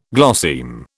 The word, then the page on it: glosseme: British English